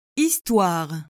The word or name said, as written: histoire